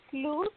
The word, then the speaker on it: Luz: My name is Luz.